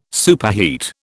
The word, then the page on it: superheat: British English